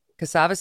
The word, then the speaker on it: Cassava: Cassava Sciences. Is that how you pronounce it? – Correct. Okay. – Cassava Sciences.